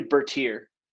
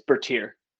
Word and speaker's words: Bertier: The now that I think about it, it's definitely Bertier. Bertier. It's Bertier. – Okay. Apologize to everybody who knows the Bertiers.